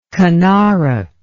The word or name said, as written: kanarak